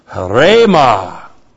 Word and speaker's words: Rhema: We have Rhema. That's the H breathing over the word. Rhema. H. R, long E, M, A.